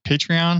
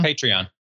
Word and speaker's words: Patreon: Florin in the GoTime FM channel also just listed as Patreon. How do you pronounce it? Patreon? – Patreon.